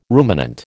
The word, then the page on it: ruminant: American English